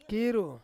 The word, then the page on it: kiru: Ansus